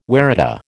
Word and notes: waratah: American English

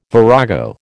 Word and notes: virago: American English

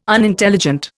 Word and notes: Unintelligent: Indian

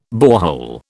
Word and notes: borehole: British English

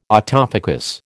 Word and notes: autofocus: American English

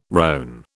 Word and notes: rone: British English

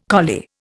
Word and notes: collie: Indian

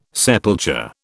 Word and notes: sepulture: British English

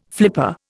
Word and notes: flipper: Indian

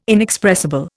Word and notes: inexpressible: Indian

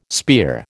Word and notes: spear: American English

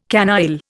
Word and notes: canaille: Indian

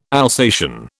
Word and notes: Alsatian: British English

